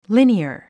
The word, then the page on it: linear: líniə r; リニアー